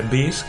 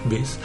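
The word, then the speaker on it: B'z: De rock así tipo Aerosmith, te diría probablemente Beast que se escribe B-Z, Beast,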